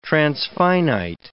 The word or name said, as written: transfinite